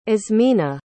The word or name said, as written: azmina